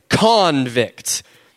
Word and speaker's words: convict: You say it like this, convict. You really emphasize that con.